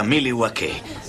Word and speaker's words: Milwaukee: A dire il vero si pronuncia Milwaukee, che in Algonquian significa la buona terra.